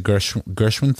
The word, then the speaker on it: Gershwin: Are they still doing it at the Gershwin Theater? – Yeah.